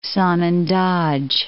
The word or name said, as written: Sanandaj